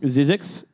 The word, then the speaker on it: Zzyzx: How do you pronounce it? – Zizix.